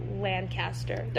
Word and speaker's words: Lancaster: People here, like when I moved here, people will like literally try to murder you if you say Lancaster. They're like, actually, it's Lancaster.